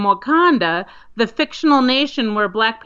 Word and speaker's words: Wauconda: It's spelled W-A-U-C-O-N-D-A, Wakanda, Illinois. But like I said, they're receiving a lot of attention because it's pronounced very similar to Wakanda.